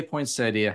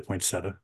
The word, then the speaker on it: poinsettia: Now, I say poinsettia. How do you pronounce it? – Depends on the day. Usually poinsettia, but poinsettia. – If mm-hmm. mood hits me, it's poinsettia.